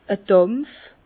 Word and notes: e Dàmpf: Bas-Rhin; Herrlisheim

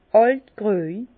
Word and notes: àlt gröj: Bas-Rhin; Herrlisheim

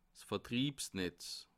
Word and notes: 's Vertriebsnetz: Bas-Rhin; Haguenau

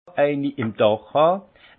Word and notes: eini im Dàch hàn: Haut-Rhin; Munster

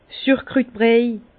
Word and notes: Sürkrütbriej: Bas Rhin; Herrlisheim

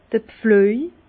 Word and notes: d'r Pflüej: Bas Rhin; Herrlisheim

